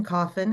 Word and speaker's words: Coffin: Allison Coffin. It's spelled C-O-F-F-I-N.